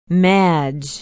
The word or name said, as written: madge